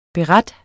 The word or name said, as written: BERETERNES